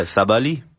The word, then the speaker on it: Sabali: But what what we're going to hear is Savali. Did I pronounce that correctly? I don't know. Did I? Yeah, yeah, Sabali.